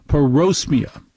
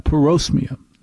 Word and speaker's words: parosmia: I think it's pronounced parosmia. I have parosmia.